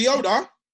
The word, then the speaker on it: Chioda: Matt Chowder. I don't know how to pronounce his name. You're that veteran referee. – Oh, Mike, what's his name? Mike Chioda? – Yeah, Mike.